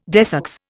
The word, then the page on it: desex: Indian